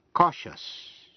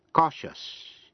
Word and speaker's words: cautious: Phần chính của bài học hôm nay là cách phát âm tiếp vĩ ngữ I-O-U-S. Đuôi I-O-U-S này có hai cách phát âm. Khi phát âm là shush, shush. Khi ở trước I-O-U-S là phụ âm như C, T hay X, nghĩa là những phần C-I-O-U-S hay là T-I-O-U-S hay là X-I-O-U-S phát âm là shush. Thí dụ như trong chữ cautious, cautious, ambitious, ambitious và anxious, anxious. Còn nếu trước những tiếp vĩ ngữ I-O-U-S không phải là phụ âm C, T hay X, phát âm thành hai âm tiết là yes.